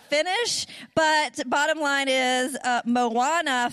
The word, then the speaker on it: Moana: Thank you, Wes, for telling me how to pronounce Moana.